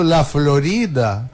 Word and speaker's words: La Florida: Ou falando errado La Florida, né? E a gente adota esse Florida, só sem fazer o rolinho, mas com a acentuação tônica do Flórida, né? Da La Florida, né?